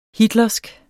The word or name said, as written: hitlersk